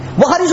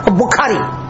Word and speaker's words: বুখারি: বহারি শরীফের ট্রান্সলেশন কিনে গড় বইয়া নিজে নিজে ফিরা, হ্যাঁ বহারি করে, আর বহারিও সহি উচ্চারণ করে বলতে পারে বুখারি বুখারি BUKHARY। বুখারি শব্দটাও সই করে উচ্চারণ করতে পারে, হ্যাঁ বুখারি করে।